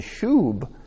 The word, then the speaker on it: shub: The Hebrew word we translate as return is pronounced something very close to shub, and it's the word that is often used in describing repentance with its intended goal of full restoration.